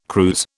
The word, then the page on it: cruse: British English